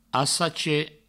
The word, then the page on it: assacher: assache